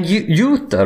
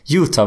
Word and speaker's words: Utah: De sa Utah. Utah. Säger, vi säger jazz. Ja, inte Utah. Vi sa Utah. Ja, men det, det, vi, vi uttalar ju ingenting annat på, på, med, det engelska uttalet.